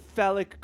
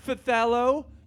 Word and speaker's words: phthalo: It's actually a blend of cafe noir and charcoal with a dash of phthalic green around my joints. – Do you mean phthalo green? – No, I don't mean phthalo. When was the last time you watched HGTV? It's pronounced phthalic.